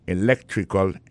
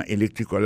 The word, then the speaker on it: electrical: Electrical engineering. Ça veut dire accent tombé dans electrical là, c'est sur deuxième syllabe là. Electrical engineering. C'est sur deuxième syllabe là. Elect là, plus appuyé.